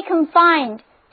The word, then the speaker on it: confine: The sense of being successful should not be confined to a small limit. 需要特别注意的是，如果把重音放在第一个音节，单词读作 confine 之后，它就变成了一个名词。